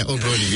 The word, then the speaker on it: Obroni: I'm probably saying this wrong, but Obroni? – Yeah, Obroni.